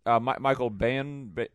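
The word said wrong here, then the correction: Michael Biehn: whatever his name is. – Whom? – This guy. – Oh, Michael Bean? – Is that how you say his name? – Yeah, Michael Bean.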